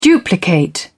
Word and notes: duplicate: British English

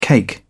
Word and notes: cake: British English

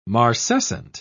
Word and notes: marcescent: British English

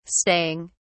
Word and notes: staying: British English

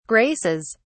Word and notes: graces: British English

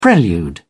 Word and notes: prelude: British English

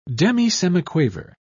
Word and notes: demisemiquaver: British English

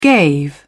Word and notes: gave: British English

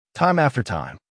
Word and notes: time after time: American English

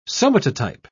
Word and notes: somatotype: British English

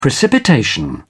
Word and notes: precipitation: British English

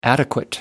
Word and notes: adequate: American English

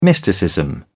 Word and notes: mysticism: British English

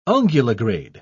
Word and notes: unguligrade: British English

